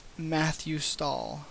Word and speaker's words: Mathew Stahl: or on Google Plus at Matthew Stahl, that's MATHEWSTAHL. Yes, my name is spelled with one T.